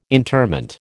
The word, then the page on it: interment: American English